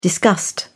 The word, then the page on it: disgust: British English